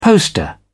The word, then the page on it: poster: British English